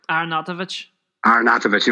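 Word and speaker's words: Arnautović: Is it Arnautovic? – Arnautovic.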